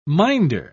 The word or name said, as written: minder